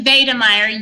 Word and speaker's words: Wiedemeyer: So it actually is Wiedemeyer, yes. It is, I believe, I've learned that only through writing this book, that Germans tend to pronounce their W's more like a V. So it's not Wittenberg, it's Wittenberg, and things like that. So it is Wiedemeyer, actually.